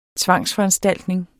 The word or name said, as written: tvangsforanstaltning